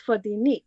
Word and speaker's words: Fotini: The stress at the end. It's Fodini. The stress is on the last.